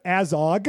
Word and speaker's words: Azog: Elsewhere, the orcs have made camp with Azog.